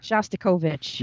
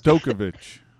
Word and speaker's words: Shostakovich: Was it Shostakhin was deemed unfit for that? – Shostakhin. – I like Shostakhin better. – Shostakovich. – Yes. – We pronounce names correctly here on the podcast. – Shostakovich.